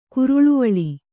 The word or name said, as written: ಕುರುಳುವಳಿ